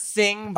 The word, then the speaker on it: Xing: Because I mean, I see Zing is X-I-N-G. – We pronounce it Sing, but Sing, whatever.